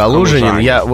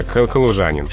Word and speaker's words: калужанин: Дело в том, что только ударение сместилось. – Калужанин, вот у меня сейчас очередной культурный шок уже был на подходе. – Нет, не надо, калужанин. – Все-таки калужанин. – Ударение сместилось. То есть эту запоминалку можно воспроизводить, имея в виду, что ударение сместилось на калужанина.